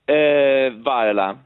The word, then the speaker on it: Viola: In Inghilterra dicono Viola o Bill Viola? – Viola. – Ok, manteniamo allora la dizione corretta.